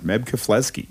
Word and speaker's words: Keflezighi: Meb Keflezighi.